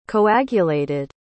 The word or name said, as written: coagulated